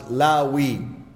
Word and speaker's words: Lawi: In Arabic, it is pronounced as Lawi. In Hebrew, I don't know.